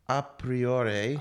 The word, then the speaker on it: a priori: So, a prior knowledge pronounced a priori. – A priori knowledge, yeah.